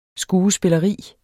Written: skuespilleri

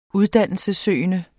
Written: uddannelsessøgende

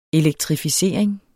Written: elektrificering

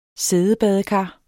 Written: siddebadekar

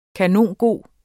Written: kanongod

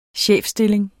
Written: chefstilling

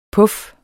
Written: PUFFENES

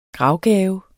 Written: gravgave

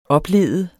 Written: oplede

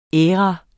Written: æra

